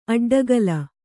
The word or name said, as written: ಅಡ್ಡಗೆಲ್ಲು